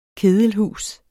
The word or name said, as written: kedelhus